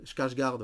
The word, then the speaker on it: Skarsgård: Skarsgård,